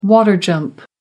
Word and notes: water jump: American English

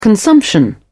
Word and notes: consumption: British English

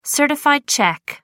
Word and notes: certified cheque: American English

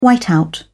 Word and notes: whiteout: British English

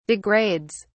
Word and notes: degrades: British English

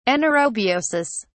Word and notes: anaerobiosis: British English

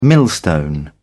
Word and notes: millstone: British English

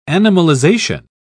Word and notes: animalization: British English

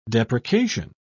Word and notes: deprecation: British English

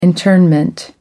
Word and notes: internment: American English

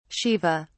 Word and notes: shiva: British English

